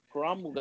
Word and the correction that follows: Kamaru: got the belt. I mean, I was pronounced this man's name wrong. – Who, Usman? – Yeah, I forget to pronounce his first name wrong. – Kamaru. – Yeah, Kamaru.